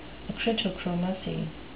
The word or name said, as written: acritochromacy